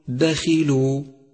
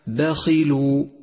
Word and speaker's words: دخلوا: خامسا لاحظ حرف الخاء المكسور في كلمة دخلوا دخلوا، نعم هو حرف مفخم دائما، ولكن تفخيمه هنا بأدنى المراتب لأنه مكسور، فالصواب أن تقول دخلوا، والخطأ الشائع دخلوا.